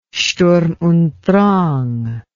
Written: sturm und drang

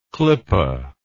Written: klippe

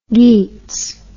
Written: geets

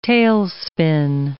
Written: tailspin

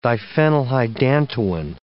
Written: diphenylhydantoin

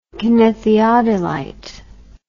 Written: kinetheodolite

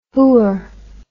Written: hooer

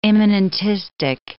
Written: immanentistic

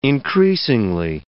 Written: increasingly

